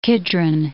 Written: Kidron

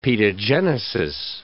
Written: pedogenesis

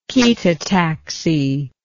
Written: chaetotactic

